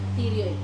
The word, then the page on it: tirioi: Wamesa